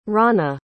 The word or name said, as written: rana